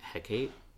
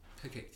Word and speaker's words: Hecate: I think you pronounce her name Hecate. – Hecate, yeah.